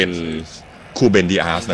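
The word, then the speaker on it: Rúben Dias: เจ้าตัวนี้ออกเสียงว่าเป็นคูเบนดิอาสนะพี่น้องตัว R ของประเทศโปรตุเกสเนี่ยออกเสียงเป็นตัว K นะฮะ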